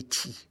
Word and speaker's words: Qi: Donc, Zheng et Qi, mais je le prononce très mal. – C'est Zheng et Qi.